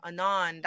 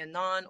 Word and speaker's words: Anon: And I thought of the term because it was actually when you the way you pronounce my name, Anon, that's exactly the original pronunciation. So I really don't mind when you call me Anon or Unknown. But I changed it to Unknown eventually because A-N-O-N is short for anonymous.